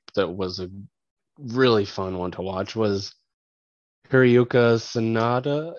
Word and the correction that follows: Hiroyuki Sanada: and Michael Shannon. I think that's how you say his name. – Yeah. Yeah. Hiroyuki Sanada.